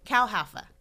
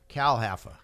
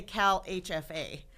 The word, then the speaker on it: CalHFA: And then there's CHAFA. – CalHAFA. – CalHAFA. All right. – Yes. – Excuse me, I mispronounced it. Well, which is easy to do, because now it did used to be CHAFA. – Did it? Okay. Yeah. And a lot of people also say CalHFA.